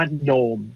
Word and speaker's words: gnome: โนม